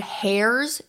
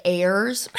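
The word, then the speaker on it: heirs: I was saying the word hairs instead of airs. Dude, it has an H on the end. Why are we saying airs? Is the H silent? Okay, apparently the H is silent.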